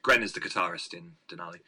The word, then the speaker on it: Denali: What brought on Denali? And am I pronouncing it right? – Yeah, yeah.